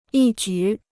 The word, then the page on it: 一举: yī jǔ